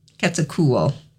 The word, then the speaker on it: Quetzalcoatl: And then there's Quetzalcoatl, Central America. – Quetzalcoatl? – Sure. I'm sorry if I'm butchering these, but I think it's Quetzalcoatl. – Quetzalcoatl.